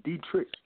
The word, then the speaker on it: Dietrich: My nickname, my name is Dietrich. Actually, my name is Dietrich. My name is German. But a lot of people can't say my name. The proper way to say it in English is Dietrich.